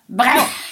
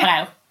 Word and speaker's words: Breuer: Ja, och det är Marcel Breuer, hur uttalar man det? – Breuer. – Jag säger det snabbt, Breuer.